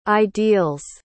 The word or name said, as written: ideal's